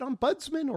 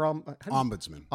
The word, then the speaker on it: ombudsman: Of those, 80% went to Democratic candidates, 20% went to Republicans, and the ESPN, is it ombudsman or ombudsman? – Ombudsman.